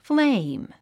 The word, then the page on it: flame: fléim; フレイム